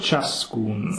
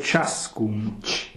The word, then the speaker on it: ciascun: So we have the C-H-I, which makes it a J-glide. – Yeah, like cane. – Exactly. – Schiavo ciascun. The hard C. – Exactly, which makes it a T with the long squiggly S.